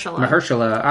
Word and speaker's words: Mahershala: Mahershala Ali in Green Book, Adam Driver in Black Klansman, Sam Elliott in A Star Is Born, Richard E.